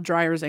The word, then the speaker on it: Dreyer: No, D-R-Y-E-R. D-R-E-Y-E-R. I'm so sorry. – D-R-E-Y-E-R. – Yeah. – Oh, I thought it was Dreyer's English. Like no. – Somebody's in the dryer. – No. – And they, and, let me out of here! Exclamation point! that's Dreyer's English.